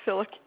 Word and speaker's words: Filuk: Carol Filuk, F-I-L-U-K.